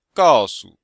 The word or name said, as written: gao4su5